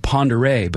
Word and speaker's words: Ponderay: Now, right next door in Idaho, there's a town on Lake Ponderay called Ponderay, but spelled phonetically P-O-N-D-E-R-A-Y.